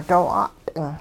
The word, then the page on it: doo 'a't'ing: hupa